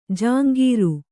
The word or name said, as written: ಝಂಕಾರ